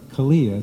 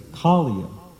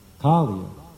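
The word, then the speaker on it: Kaliya: It's not, it's the same, the serpent's name is not Kalia, it's Kalia. The long, the first syllable is long, Kalia.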